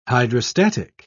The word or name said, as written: hydrostatic